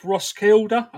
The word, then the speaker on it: Roskilde: You're from a place called Roskilde. Have I said that right, in Denmark? – That's absolutely correct.